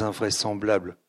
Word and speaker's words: invraisemblable: a déclaré cette linguiste connue surtout pour ses invraisemblables. On prononce bien vraisemblable, invraisemblable, bien qu'il n'y ait qu'un S, et chapeau à fleurs.